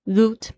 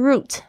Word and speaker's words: route: 接下来是路线图 route map。 Route map，这个 route 就是线路，然后 map 就是地图。 Route map，然后这个 route 还会有另外一个发音是 route，哦，两个都会有人用，所以把它记起来，route 或者是 route 都可以。